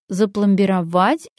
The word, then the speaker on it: запломбировать: Зуб запломбировать или запломбировать? Запломбировать. Рана кровоточит, зуб запломбировать.